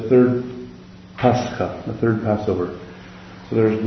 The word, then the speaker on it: Pascha: It's even embedded in their translation of what the word Pascha means, as in passage, rather than Passover.